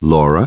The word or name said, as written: Laura